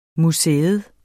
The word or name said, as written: museers